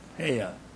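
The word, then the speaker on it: hayah: So, the Hebrew word for I am is, I don't know if I can pronounce this, it's H-A-Y-A-H. Heya.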